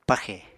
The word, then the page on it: paxè: Apatani